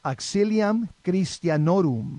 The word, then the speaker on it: Auxilium Christianorum: which in Latin is pronounced Auxilium Christianorum.